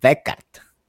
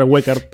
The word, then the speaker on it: Weckert: es que cuando se usa la W, la mayoría de las veces se pronuncia como B, como, como Volkswagen. Entonces es Simon Beckert.